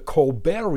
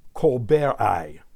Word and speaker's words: Colbert: Aptostichus Stephan Colberti, said Colbert, and all I had to do was shamelessly beg on national television. Would we observe that the final T in Colbert's name is silent?